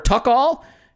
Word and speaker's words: Tuchel: So Andrew Brook at Andy Brook, A-N-D-E-B-R-O-O-K, and Brook, and Ebrook says, how do you pronounce T T-U-C-H-E-L. Is it Tuchel or Tuchel? We actually have a response to that saying it's Tuchel.